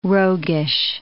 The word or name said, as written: roguish